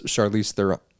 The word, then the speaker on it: Charlize Theron: It's Charlize Theron.